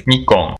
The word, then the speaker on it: Nikon: He said, so I'm going to send you the proper pronunciation as spoken by Japanese people, which therefore has to be correct, doesn't it, really? So this is the proper pronunciation, which he sent me today. – Nikon. – It's off the internet. – Nikon. – Nikon. – Nikon. – Yeah, yeah. – So they're Nikon. Kind of more like Mikon, aren't they?